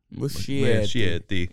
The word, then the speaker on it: Muschietti: But yeah, so this movie is directed by, I'm going to keep saying his name wrong, Andy Muschietti. – Muschietti.